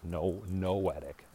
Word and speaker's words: noetic: Not only is it poetic, right, because it's exactly spelled like poetic. It's noetic, N-O-E-T-I-C.